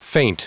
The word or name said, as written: feint